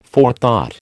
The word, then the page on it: forethought: American English